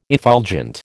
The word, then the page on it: effulgent: American English